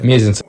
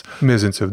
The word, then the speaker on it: Мезенцев: Мезенцев или Мезенцев, как правильно ударение? – Мезенцев. У вас. – Мезенцев, да.